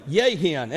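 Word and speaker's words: yayin: is a little word that is pronounced this way, yehin.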